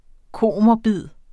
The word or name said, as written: komorbid